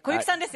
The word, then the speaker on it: 小雪: 小さい雪と書いて小雪さんですよね。